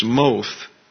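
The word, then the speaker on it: moth (מוֹת): You have muth, the cal infinitive absolute, and tamuth, the cal imperfect. Actually, I mispronounced the first one. It's moth, M-O-T-H. That would be a long O in pronunciation.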